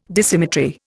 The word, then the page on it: Dissymmetry: Indian